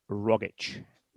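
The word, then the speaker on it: Rogić: And that man is Tom Rogic or Rogic. – Yeah. – Right. So what is the actual pronunciation of his name? – The actual pronunciation of his name, as he says his own name, is Tom Rogic.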